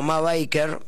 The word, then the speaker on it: Ma Barker: También conocida como Ma Barker, que es como se dice en realidad, yo siempre lo dije mal.